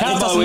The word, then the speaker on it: Cabo Verde: It's Cabo Verde. – it's none of them. – But Cabo is right.